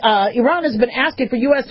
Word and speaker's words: Iran: We don't say Iran. We say Iran. We've been saying Iran for years. But Iranians don't like it. It's pronounced Iran.